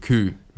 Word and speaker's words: coup: I think, but I don't think the P is pronounced. – Okay, well, massive coup.